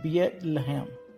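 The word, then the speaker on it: Beit Lehem (בית לחם): Bethlehem, in Hebrew, the city's name is pronounced Beit Lehem.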